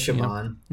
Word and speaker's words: Siobhan: I think it's pronounced Siobhan. It's C.O. Bahan. Is that right? – Yeah. – Yeah, that's Siobhan.